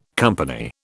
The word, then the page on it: company: British English